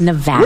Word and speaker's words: Nevada: It's Nevada, sir. Please say it correctly. It's Nevada.